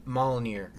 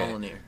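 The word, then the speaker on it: Mjölnir: Mjolnir. – Mjolnir. God, I butchered that. – Dude, that's why I wasn't in your tribe. – Mjolnlin. – Okay. – Mjolnir.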